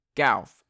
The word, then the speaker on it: Gauff: Coco Gauff. Gauff. Okay, that makes sense, Gauff.